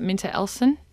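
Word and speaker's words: MinterEllison: For those of us who might not know, did I say that right, Minter Ellison? – Yeah, Minter Ellison.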